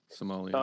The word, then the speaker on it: sommelier: Yeah, sommelier.